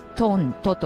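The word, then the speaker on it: TONTO: サイザーはとっても巨大な、壁いっぱいに広がるようなアナログシンセサイザーで、トントと呼ばれるね、巨大なシンセサイザーがリリースといいますか、その当時開発されたわけですね。まあ、ジ・オリジナル・ニュー・ティンブラル・オーケストラの略でトントと読むんですけれども、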